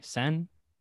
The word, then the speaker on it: Seine: I don't know how to say it. S-E-I-N-E. Seine? – Seine.